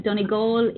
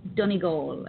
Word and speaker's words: Donegal: But Donegal is Donegal, as opposed to Donegal.